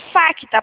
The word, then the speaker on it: Facta: É Facta, né? – Beleza. Isso, Facta, Tá. – pode me pôr no Viva Voz. O C é mudo, tá? – Não, tranquilo, tá no Viva Voz aqui.